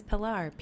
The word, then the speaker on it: Pilar: So when I was little, it was, my name is Pilar, P-I-L-A-R.